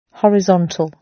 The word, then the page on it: horizontal: British English